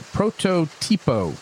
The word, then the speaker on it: Prototipo: and this is the Matt Booth Portotipo. I gotta say this right, Prototipo. – Prototipo.